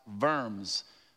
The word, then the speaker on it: Worms: And then Worms is the name of a German town pronounced Worms.